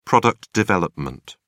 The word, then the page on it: product development: British English